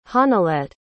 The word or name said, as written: honilette